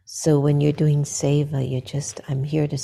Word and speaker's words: Seva: It's either Siva or Seva. Seva. seva. – Yeah, keep doing Seva, which is selfless service.